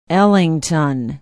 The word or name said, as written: ellington